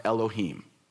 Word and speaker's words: Elohim: So the word for God is a Hebrew word that's plural, and it's pronounced Elohim.